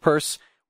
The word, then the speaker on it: Peirce: We all know it's spelled Peirce, it's pronounced Peirce. We can say either one.